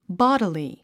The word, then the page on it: bodily: bάd ə li; ボォディリィ